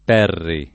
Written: Perri

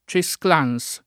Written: Cesclans

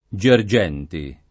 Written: Gergenti

